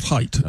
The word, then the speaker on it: Haidt: Oh, it's spelled not like it's pronounced. It's Haidt. – Haidt. – Yeah, and he pronounces it Haidt.